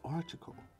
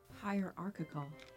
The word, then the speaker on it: hierarchical: As soon as I say that, you're like, oh, you didn't pronounce the I. But it's not archical. But how do I say it correctly without leaving out that? Hierarchical. Hierarchical? – No. – Tried to pronounce the I, and that's what the fuck happened. That's what I get.